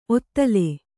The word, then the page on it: ಔತಳ: autaḷa